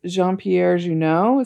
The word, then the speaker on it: Jean-Pierre Jeunet: So it's at least part of, it's Jean-Pierre Jeunet. Is that how you say it? – Yeah.